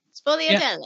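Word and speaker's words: sfogliatelle: Sovelegelte. – Well, I know that's wrong.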